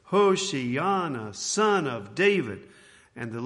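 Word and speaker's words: Hoshiana (הושענא): And let's start off by just saying the word Hoshiana and put the accent on the ana. Can we say that together? Hoshiana, Hoshiana.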